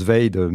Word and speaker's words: vade: Euh, voilà, donc on le prononce Vade,